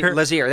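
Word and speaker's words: Lazear: how'd I say his name? – Lazier. Jesse Lazier. There we go.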